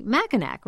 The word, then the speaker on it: Mackinac: It's spelled M-A-C-K-I-N-A-C. Mackinac, right? Wrong. We heard from many Michiganders. How do you pronounce it? – I pronounce it just like the British overcoat, Mackinac.